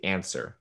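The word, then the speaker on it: Ancer: I'm going to say Answer. That's how Answer. You pronounce it.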